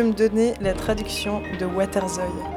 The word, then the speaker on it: waterzooi: Ok, pour tous les écouteurs flamands, waterzooi.